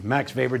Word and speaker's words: Weber: You probably know that name, Max Weber, W-E-B-E-R. It sounds V, but German, you know, they don't know how to pronounce anything.